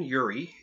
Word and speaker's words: Urie: Now, the lead singer, Brendan Urie, if that's how you pronounce that. – That is correct.